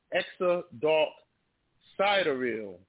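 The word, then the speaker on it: Exa Dark Sideræl: And the other one is with the name Exadark, S-I-D-E-R, Ether Psi L. Her name is pronounced Exadark Sidereal. Sidereal, that's how they're pronouncing it.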